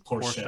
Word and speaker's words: Porsche: Apparently, that's how you pronounce it. Porsche. Porsche. Porsche for the Germans.